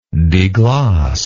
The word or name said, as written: degloss